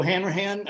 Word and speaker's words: Hanrahan: My name is Joe Hanrahan.